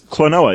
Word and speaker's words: Klonoa: And for Wii U virtual console is Klonoa, Empire of Dreams. Am I saying that wrong? – No, that's Klonoa is correct. – It's not like quinoa, where you have to like pronounce it right? – No, Klonoa is not like quinoa.